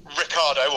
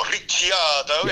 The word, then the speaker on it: Ricciardo: And I think Danny Ricciardo, or Ricciardo, yes, if we're going by NRF1 podcast rules. – By correct pronunciation generally, yes, yeah.